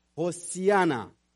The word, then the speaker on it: Hosanna: Now, the word Hosanna, it is a Hebrew word which is pronounced as Hosiana.